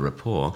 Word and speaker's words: rapport: I've largely sat at the bar, which has allowed me to develop a rapport, which I say in the same way I'd say restaurant, not pronouncing the T at the end.